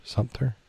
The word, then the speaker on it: Sumter: Sumter. Sumter, Sumter. I added an extra syllable in there, I think.